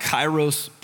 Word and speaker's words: Kairos: Mark is a covenant member here at Risen and has been a part of a ministry called Kairos Prison Ministry, did I say it right? Mm-hmm, yep.